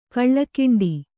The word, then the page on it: ಕಳ್ಳಕಿಂಡಿ: kaḷḷakiṇḍi